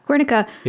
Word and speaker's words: Guernica: Guernica? – Yeah, Guernica.